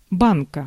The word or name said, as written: pot